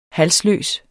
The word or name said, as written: halsløs